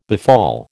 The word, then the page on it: befall: American English